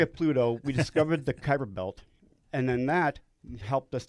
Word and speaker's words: Kuiper Belt: Which could be the entire mass of the Kuiper Belt. How do you say it? – Kuiper Belt.